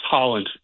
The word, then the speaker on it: Haaland: But Holland is the best player on Norway, and his last name is pronounced Holland.